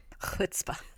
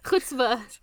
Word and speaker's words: chutzpah: Chutzpah. – Chutzpah. You'll notice that in my notes it's spelled correctly with C-H. I was explaining to Mike that you have to ha when you say Hanukkah.